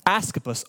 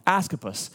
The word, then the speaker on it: Asclepius: It's this god, this false god named Asclepius. He's the god of healing. Asclepius. I'm sorry, I said it wrong. Asclepius.